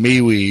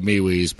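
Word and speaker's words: Mewes: And, you know, they always used to pronounce his name Mewees. – Yeah, it's M-E-W-E-S. – Right? Yeah. So I call him Mewees.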